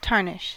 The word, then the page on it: tarnish: American English